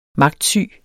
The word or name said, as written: magtsyg